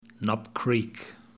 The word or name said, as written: Knob Creek